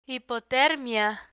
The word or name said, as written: hipotermia